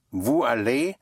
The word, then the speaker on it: vous allez: V-O-U-S. – Right, V-O-U-S. The S, the silent S, K can be pushed into the following word if the following word starts with a vowel. And you have, and it takes on the sound of a Z or Z.